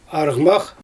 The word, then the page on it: arGmax: Xyzyl